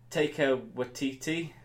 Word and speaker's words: Waititi: Taika Waititi, is that how you say his name? – Yeah.